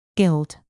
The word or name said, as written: guild